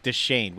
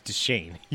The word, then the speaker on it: Deschain: I swear we had an argument about this, just like Deschain, which is the only way to pronounce his last name. – Deschain? – God damn it. It's Deschain. You monster.